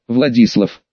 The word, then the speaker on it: Владислав: Это ее местный партнер, Владислав. Ха, он требует именно на и ударение ставить.